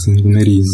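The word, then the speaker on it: Saint Mary: ラんてレベルでしたっけセイントメリー、セイントマリーって書いて、まあ多分発音的なセイントメリーのかな。セイントメリー。メリーってことね。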